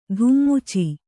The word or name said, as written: ಧುಮ್ಮುಚಿ